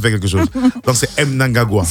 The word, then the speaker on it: Mnangagwa: Le portrait, aujourd'hui portrait consacré donc à Emmerson Mnangagwa. C'est bien cela, je prononce comme ça Mnangagwa. – Mm-hmm. M, c'est pas N. – C'est M. Oh non, c'est Mnangagwa.